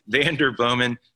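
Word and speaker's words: Vanderbloemen: Also grateful from Texas to get the shout out there from William Vanderblomen. William, grateful to have you, CEO of the Vanderblomen. I got to say it right. It's hard to spell, but I can say it. Vanderblomen Search Group.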